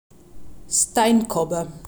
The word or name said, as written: Steinkobbe